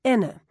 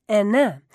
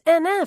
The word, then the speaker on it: Henin: They put the emphasis on the first syllable and then just trail off into a vague, indistinguishable vowel, Henin. Henin hasn't lost a set the entire tournament. Henin hits a sublime one-handed backhand. Listen up, you tennis experts. It's Henin. Emphasis on the second syllable. And the na is pronounced as in cousin, voisin, tartartin. It's Henin, for God's sake.